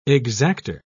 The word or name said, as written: exactor